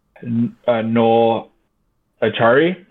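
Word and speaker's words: Noel Acciari: They also picked up Noel Achari.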